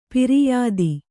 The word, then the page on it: ಪಿರಿಯಾದಿ: piriyādi